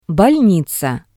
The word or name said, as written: bolniza